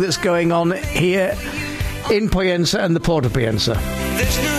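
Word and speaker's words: Pollensa: That's Poyensa, spelt with a double L.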